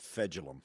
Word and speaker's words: Fejedelem: And I look at the phonetic pronunciation, and I still can't get it. It's Fedulum. Fedulum. – Okay. It doesn't. It's not spelled how you pronounce it.